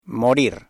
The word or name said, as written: morir